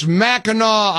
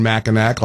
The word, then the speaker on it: Mackinac: It's Mackinac Island. I know. I knew better. I've never been, but I knew better. Of course, it's spelled Mackinac, and I was saying Mackinac like a dummy.